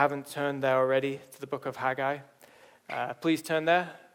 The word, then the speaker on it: Haggai: So Haggai. And if I say Haggai, I don't mean to. It's Haggai.